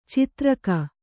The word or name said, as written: ಛತ್ರಕ